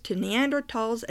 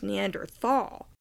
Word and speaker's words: Neanderthal: Tal means dale or valley in German, spelled with a TH, which is why so often the word is pronounced Neanderthal.